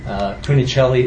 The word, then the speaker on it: Cunicelli: Cunicelli is spelled C-U-N-I-C-E-L-L-I.